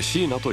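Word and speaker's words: 4na: TOKIO HOT 100、今週 78位、初登場、椎名最上級の愛を。 2020 年にデビューした沖縄出身のシンガーソングライター、椎名。数字の 4 にアルファベットで NA と書いて椎名と読みます。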